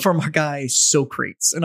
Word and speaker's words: Socrates: You're like a modern Socrates. – Sir, it's pronounced Socrates. – Socrates, my bad.